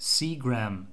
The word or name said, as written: seagram